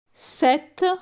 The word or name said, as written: 7